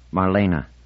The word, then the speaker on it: Marlena: Three syllables, Marlena, with a little trill to the R.